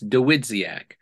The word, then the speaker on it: Dawidziak: all right, if you're looking at it, just break it down phonetically because it actually was phoneticized when they came in. It's Dawidziak.